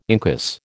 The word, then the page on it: incus: American English